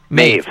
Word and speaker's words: Maeve: Maeve.